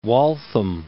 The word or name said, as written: Waltham Forest